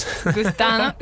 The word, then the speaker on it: gusano: You'll have some gusanos. – Eat some worms. – Which is some worms. – Gusano. Wait, how do you say? – Gusano.